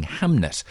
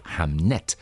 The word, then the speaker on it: Hamnet: But before all that, Maggie O'Farrell has realised something of a long-held ambition in writing Hamnet. That's spelt with an N, not an L. Hamnet.